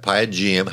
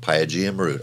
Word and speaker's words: Pygeum: All right, Pygeum. How do you spell that? – P-Y-G-E-U-M. – All right, Pygeum root.